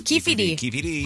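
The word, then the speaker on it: Keffe D: Is it Keffy or Keffy D? – Keffy D.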